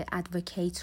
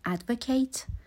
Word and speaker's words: advocate: فقط تلفظش یکم متفاوت میشه. وقتی که فعله میگیم advocate, وقتی که اسمه میگیم advocate.